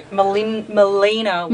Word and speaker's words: Melina Wylie: And Melinda Wylie. – Yeah. Melina Wylie.